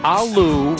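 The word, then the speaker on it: aloo: Really? Alu rhymes with Lou and it means potato.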